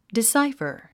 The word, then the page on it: decipher: disáifər; ディサイファ